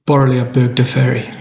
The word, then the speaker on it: Borrelia burgdorferi: How do you pronounce it? – Borrelia burgdorferi. Is that it? – Yeah. Borrelia burgdorferi. That's the one.